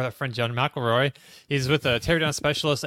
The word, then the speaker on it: McElroy: McElroy. Is it McElroy? – Yeah. – God damn it! – It's just like a Big Mac, like a gun or right.